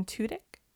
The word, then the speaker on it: Tudyk: Alan Tudyk. – Ooh. – Did I say his last name right? – I believe so.